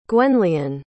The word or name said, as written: gwenllian